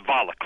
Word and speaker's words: Valick: I'm professor, and it's Valick, rhymes with Pollock.